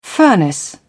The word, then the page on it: furnace: British English